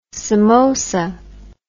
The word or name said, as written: samosa